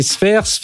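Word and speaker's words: Sfeir: Didier, que vous avez peut-être aperçu, tu es venu dans une émission parler de développement, de justement l'avenir des développeurs, directeur général chez Sphere. Alors, pour ceux qui nous écoutent à radio, ça s'écrit S-F-E-I-R. C'est bien ça.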